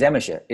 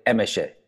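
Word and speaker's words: Emese: Her name is Emisha. It's impossible for Americans to pronounce her name, more or less impossible. Emisha, it's E-M-E-S-E. So when we're in India, they pronounce your name right away, Emisha.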